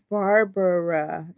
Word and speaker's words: Barbara: You know, my name is Barbara. It's three syllables, but everybody calls me Barbara.